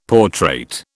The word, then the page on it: portrait: British English